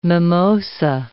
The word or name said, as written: mimosa